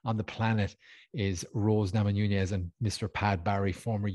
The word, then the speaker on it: Namajunas: Rose Namajunas versus Carla Esparza. Is that pronounced correctly? – Sounds about right.